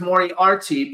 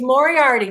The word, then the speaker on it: Moriarty: And make sure it's Moriarty because sometimes people forget the R before the T-Y. So it's Jennifer Moriarty. Jennifer L. Moriarty on Instagram. – Isn't it pronounced Moriarty? Moriarty? – It's Moriarty. That's right.